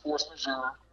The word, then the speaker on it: force majeure: Yeah, I mean, I think that you know, honestly, you have a lot, it's pronounced force majeure,